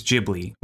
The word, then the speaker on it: Ghibli: The way to pronounce it for the actual Studio Ghibli, is Ghibli with the like a J sort of sound.